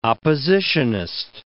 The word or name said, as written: oppositionist